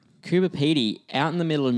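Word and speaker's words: Coober Pedy: So we are heading to, how do you say that? – Cooper Petey. Yeah. Just exactly how I might have said Petty.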